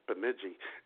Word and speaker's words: Bemidji: Although I think it's actually pronounced Bemidji.